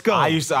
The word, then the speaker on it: Kosciuszko: I truly thought it was Kajuko until 1 second ago. – I know I was saying it's Kosciuszko.